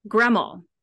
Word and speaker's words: Gramoll: Gramoll.